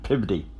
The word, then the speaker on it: Peabody: And both pronounced Worcester, Gloucester, and Peabody as Wista, Gloucester, and Pibody.